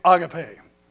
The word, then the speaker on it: agape: I'm going to pronounce it the way it's actually supposed to be pronounced, and you can pronounce it the way we've all heard it for years. And that's the word agape. The word is actually agape.